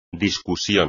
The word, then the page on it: discusión: espagnol